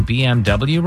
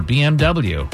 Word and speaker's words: BMW: Number 5, apparently we've been pronouncing BMW wrong all these years. In German, a W is pronounced V. What does BMW stand for, or BMW? – Better motor vehicle.